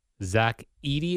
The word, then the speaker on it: Zach Edey: Zach Edey is how, yep.